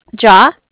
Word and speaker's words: Jia: Jaw, JIA.